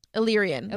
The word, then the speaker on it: Illyrian: How do you say that? Illyrian? – Illyrian is what I say.